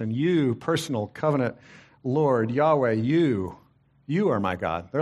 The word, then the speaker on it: Yahweh: It's pronounced often Yahweh or Jehovah.